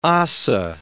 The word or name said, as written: ossa